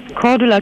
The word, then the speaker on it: Cordula: Cordula Kienes.